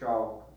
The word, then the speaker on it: Chou: He worked in the archives of the Chao, C-H-O-U, it's spelled, court in ancient China, a period of about 500 B.C.